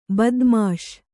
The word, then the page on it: ಬದ್ಮಾಶ್: badmāś